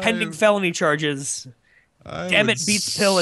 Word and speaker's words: Beats Pill: And that is my Beats pill. – I think you put the emphasis on the wrong syllable there. Did you mean your Beats pill? – My Beats pill, yes.